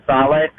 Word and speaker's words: Saleh: What is it, Robert Saleh from San Francisco? – Yep.